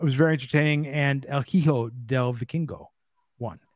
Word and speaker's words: hijo: El Hijo del Vikingo. That's I'm working on the Duolingo. 135 day streak. – Hijo. – Good job. Oh, it's the G with the H. – We don't pronounce the H.